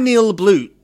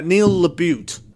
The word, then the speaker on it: LaBute: And it's by Neil LaBute. Neil LaBute. That's how you pronounce it.